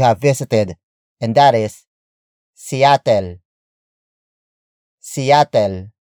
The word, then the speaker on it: Seattle: It is the Tagalog pronunciation for Seattle, which is located in Washington State in the Pacific Northwest. Some Tagalog speakers may pronounce it as Shattel. Shattel. Shattel. This is mainly due to the S-I-Y letter combination, which is commonly pronounced as sh, like shout.